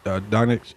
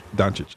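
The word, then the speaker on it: Dončić: And LeBron's team is Giannis Antetokounmpo, Stephen Curry, Luka Doncic. I keep saying his last name wrong. – Doncic.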